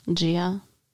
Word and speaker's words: Gia: Gia. – Oh, is that how it's pronounced? – You sat through the 2-hour-long movie.